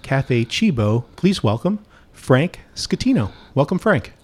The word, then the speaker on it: Scatino: Yes, you did, Scatino. That's right. – Right. Like the way I remember, it's like school. – Yeah, school. School. That's the way I always advise people to pronounce it.